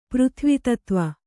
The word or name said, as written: ಪೃಥ್ವಿತತ್ವ